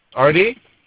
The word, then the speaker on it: Ardy: Artie? – Can you spell me? Spell that for me? – A-R-D-Y.